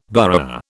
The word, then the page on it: burgher: British English